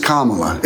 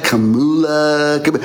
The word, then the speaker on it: Kamala: Like her name is Kamala. It's not difficult. It's like the word Pamela. It's just three syllables.